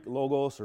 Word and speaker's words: logos: The most common is the word in the Greek, logos or logos, depending on how you want to pronounce it.